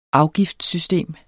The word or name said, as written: afgiftssystem